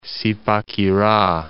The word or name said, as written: Zipaquira